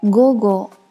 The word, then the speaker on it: 午後: 大家都喝过麒麟的午后红茶，这个午后读作 gogo。映画，映画，汉字写作映画，映画指的就是电影。英語，英語，英语，语言的语字在这里读作 go，go。